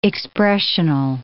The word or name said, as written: expressional